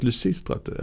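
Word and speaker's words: Lysistrate: Ja, så det uttalas Lucistratte.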